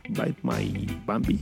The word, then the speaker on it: Bite My Bambi: de que pues, otras bandas como los Fire on France y como Bite My Bambi, Bite My Bambi, así sería la pronunciación correcta, eh, van a sacar disco, los Interrupters, entonces este, Cat Bite también van a sacar disco.